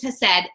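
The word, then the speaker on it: hesed (חסד): It's hesed. It's spelled with a CH, but we think it's hesed.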